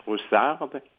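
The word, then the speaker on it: Ryszard: Alors, je crois que son prénom se prononce Roussard Kapuscinski,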